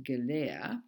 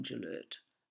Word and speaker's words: Gellert: I know the French would pronounce it Gellert because his surname is spelled G-E-L-L-E-R-T. But Leon Gillert wasn't French. He had Hungarian ancestry, I believe. And in Australia, we tend to pronounce names fairly phonetically. So I'm going to call him Leon Gillert.